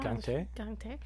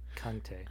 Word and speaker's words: Gang-tae: And then the letter in Korean, it's between a G and a K, if you were to pronounce it. So sometimes it sounds like Gang-tae and sometimes it sounds like Kang-tae. But it's the way that it's pronounced, it's more abrupt. So it's not necessarily one or the other, it's in between.